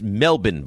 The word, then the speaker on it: Melbourne: It's pronounced Melbourne, by the way, not Melbourne. That really annoys us when people say it wrong.